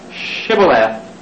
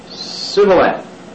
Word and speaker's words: shibboleth: But it tells us in the Bible that they could not hear to frame to pronounce the word shibboleth, for they said sibboleth.